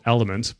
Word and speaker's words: LMNT: And then the other one is called Element, but it's spelled L-M-N-T, electrolyte powder.